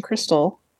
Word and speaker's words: Kristal: I am at Hayden Crystal on everything, and my last name is K-R-I-S-T-A-L. It's pronounced like the meth, but spelled like a stripper.